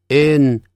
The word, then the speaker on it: thin: 114 页，第七组 free 三或者三个 three 所以自由的念成 free 三个 three 你那个舌头一定要吐出来 three 第八鱼鳍 fin 瘦的 thin 舌头吐出来的